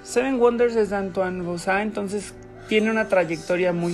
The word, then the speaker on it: Bauza: Este juego lo hizo Antoine vos se, escribe literal B-A-U-Z-A. Antoine Bausa. Que igual supongo que estando en México está bien pronunciado. Yo tengo la firme convicción de que se, de que se pronuncia Bosa.